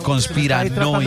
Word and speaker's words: conspiranoico: ¿Se dice así, conspirobónico? – No, señor, conspiranoico. – De decir bobo. – Conspiranoico. – Ah, conspiranoico. – Se dice conspiranoico.